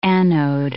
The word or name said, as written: anode